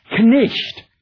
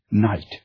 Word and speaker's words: knight: There are, as in Old English, no silent letters in Chaucer's language. You pronounce everything. Mariage, not marriage. Knicht, not knight.